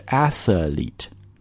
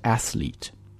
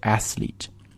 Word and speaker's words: athlete: 第一个是 athlete，第二个是 athlete。哎，哪个对呢？应该是第二个对。为什么呢？我们仔细来看一下这个单词的拼写，你会发现 th 这个 th 之后是没有元音出现的，所以你不要读成 athlete。而应该直接是 athlete 然后后面的这个 e 呢是一个长音的 e 啊